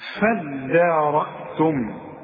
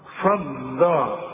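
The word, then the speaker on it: فادّارأتم: نفسا فدارأتم، دي عايزة منك فن في النطق، ما تقولش فضا، الدال الدال والمد بتاعها مرقق، هتقول فدان، عارف على وزن ايه فدان؟ شوف فدان ارض، فدان شوف الدان، هتقول لي ايه فضان ولا تقول لي فدان؟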